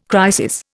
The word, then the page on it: crisis: Indian